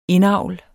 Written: indavl

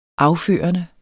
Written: afførende

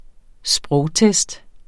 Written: sprogtest

